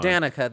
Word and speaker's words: Danica: So one of the shout outs this week um, goes to Danica Roem. Am I saying that correctly? – Yeah. Mm-hmm. Danica, though.